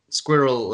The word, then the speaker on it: squirrel: Like they were saying squirrel like how say like a British person or like a non-American person would say it, but they were saying it with two syllables. And I realized that in an American dialect, it's usually one syllable.